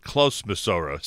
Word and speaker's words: Clos Masora: And again, I guess we'd pronounce it Clos Masora, C-L-O-S, Masora.